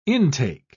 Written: intake

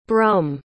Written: brum

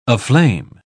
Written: aflame

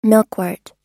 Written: milkwort

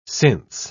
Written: since

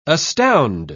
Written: astound

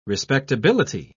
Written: respectability

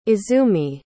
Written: izumi

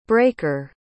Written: braker

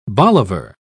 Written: bolivar

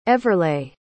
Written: everleigh

